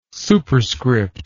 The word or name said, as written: superscript